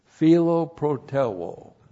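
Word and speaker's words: philoprōteuō: That's how it looks in the Greek, P-H-I-L-O, and you recognize that with philos, for love. And then you have P-R-long-O-T-E-U, long O, philoproteuo.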